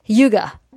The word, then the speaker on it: hygge: You also had an admission based on the Scandinavian thinking of, I think I'm going to pronounce this correctly, hygge, spelled H-Y-G-G-E.